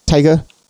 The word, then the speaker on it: Taika: Taika.